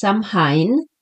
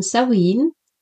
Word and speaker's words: Samhain: Das letzte und das erste Jahreskreisfest ist Samhain, geschrieben wird es Samhain, aber ausgesprochen Samhain, und das ist ein Fest der Ahnen und des Übergangs.